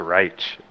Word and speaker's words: Reich: I said the littlest Heich. It's Reich.